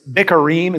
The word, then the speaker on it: bikkurim: The word first fruit in Hebrew is bikkurim, is how you pronounce it.